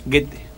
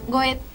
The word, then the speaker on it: Goethe: Goethe. – Bueno, mi pronunciación. Para aquellos que pueden pronunciar alemán es así, para aquellos que somos bastante legos en la materia, decimos Goethe.